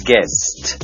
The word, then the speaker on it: guest: G-U-E-S-T guest ศัพท์คำนี้ยังหมายถึงแขกที่มาเยี่ยมก็ได้นะครับออกเสียงให้ถูกต้องนะครับ guest เสียงสุดท้ายคือ st ครับ